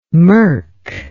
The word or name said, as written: mirk